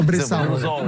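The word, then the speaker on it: bresaola: Si, la mais voilà, voyez, vous voyez que je ne l'avais pas prononcé comme il fallait. pas tout à fait prononcé la, la Bressaola. – La Bressaola. Voilà.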